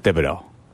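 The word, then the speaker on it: Thibodeaux: Is it pronounced Thibodeau? – Kayvon Thibodeau. Yeah.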